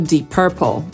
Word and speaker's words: Purple: Nazwę kolejnego zespołu moglibyśmy przetłumaczyć jako ciemny fiolet, bo jest to Deep Purple. I właściwie to purple to wymowa amerykańska, po brytyjsku powiedzielibyśmy purple, a w Polsce z jakiegoś powodu przyjęło się, że to Deep Purple. Przecież tam nie ma żadnego A.